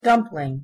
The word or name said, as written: dumpling